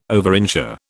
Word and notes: overinsure: British English

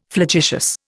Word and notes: flagitious: Indian

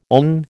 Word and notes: own: American English

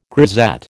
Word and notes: grisette: American English

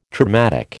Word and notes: traumatic: American English

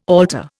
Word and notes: altar: Indian

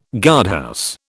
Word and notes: guardhouse: British English

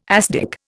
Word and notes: asdic: Indian

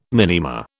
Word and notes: minima: British English

